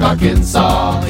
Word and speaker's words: Arkansas: The S is silent in Des Moines, Iowa, and also in Little Rock, Arkansas.